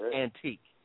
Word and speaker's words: Antiq: You know, the baby's name was Antique, A-N-T-I-Q, pronounced Antique.